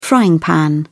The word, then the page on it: frying pan: British English